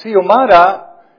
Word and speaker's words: Xiomara: The lady Xiomara, in case you're wondering how to pronounce, the X-I-O-M-A-R-A, that X, she pronounces it like it's an S, Xiomara.